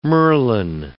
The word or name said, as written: merlon